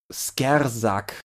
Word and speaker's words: Skrzzak: schreiben sich, ich lese warte mal, nee, die Aussprache hilft mir nicht, die schreiben sich S-K-R-Z-Z-A-K, aber hinter diesem Konsonantenkram verbergen sich äh, offensichtlich Skavenartige Geschöpfe oder so. Skersack, glaube ich, soll man es aussprechen.